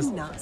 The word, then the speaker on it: Seina: Seina. Ça s'écrit un peu comme la Seine, mais... – Voilà, avec un A à la fin, Seina. On prononce le I aussi. – Comme si on avait un tréma au-dessus.